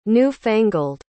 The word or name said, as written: new-fangled